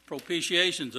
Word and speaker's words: propitiation: Propitiation's a big word, isn't it, kids? And I think that's the way we pronounce it, propitiation, though it sure looks like it'd be pronounced a different way.